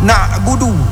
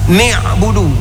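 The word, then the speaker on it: na'budu (نَعْبُدُ): Au nun na'budu. Nun pada kata na'budu dibaca dengan ni'budu dengan kasrah. Itu lahen.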